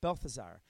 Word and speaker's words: Balthazar: Balthazar.